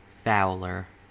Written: fouler

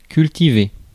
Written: grow

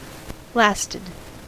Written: sürülmek

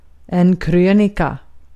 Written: krönika

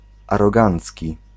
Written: arrogant

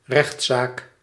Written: acción

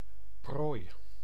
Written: proie

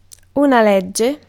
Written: act

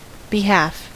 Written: behalf

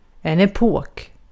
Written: epok

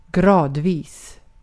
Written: gradvis

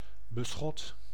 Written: paroi

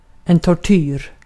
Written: tortyr

